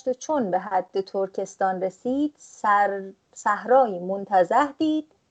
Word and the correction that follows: متنزه: اگر درست تلفظ بکنم که یعنی چشم نواز علف و آب بسیار متنزه. – چی گفتم؟ متنزه عذر میخوام.